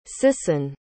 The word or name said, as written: sison